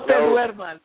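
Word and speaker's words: te duermas: no, dramas. Am I saying that right? – No, te duerman. Don't fall asleep.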